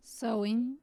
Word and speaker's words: Samhain: For my grandparents, they did celebrate Samhain, which is how we call it in the Northwest. – Right. – In English is sowing. I think I'm pronouncing it okay.